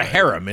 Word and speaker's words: cherem: It's not a harem, is it? – H-E-R-E-M. – Oh, H-E, thank God. – C-H-E-R-E-M. – How do you pronounce that? – Harem. – Yeah, harem, I think.